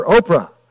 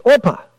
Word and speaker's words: Orpah: But some in her family did not know how to pronounce the name, and they called her Oprah. But really, her name's Orpah.